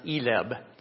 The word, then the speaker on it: ILEB: And it goes by those initials, in which the name in Spanish is ILEB, pronounced ELEB.